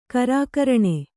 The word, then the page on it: ಖರಕಿರಣ: kharakiraṇa